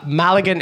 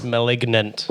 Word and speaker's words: Malignant: Maligan is how I prefer to say it, yes. – Is that not how you say it then? – I believe it's pronounced Malignant.